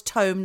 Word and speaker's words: tome: It was tome, not tomb.